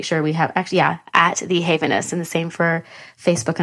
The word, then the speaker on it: HAVENOUS: HAVENOUS, and that's spelled HAVENOUS. And I was pronouncing it wrong for months. So we're going to try to get it together today. But it is HAVENOUS.